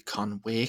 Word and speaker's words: Conwy: That would be Conway Castle is how it's pronounced, but that is not my answer.